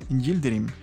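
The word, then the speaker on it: Yıldırım: Y bueno, hablemos del Canelo Álvarez, porque el boxeador mexicano este fin de semana derrotó al boxeador Abni Gildrin espero lo haya pronunciado bien, Yildirim, Abni Yildirim.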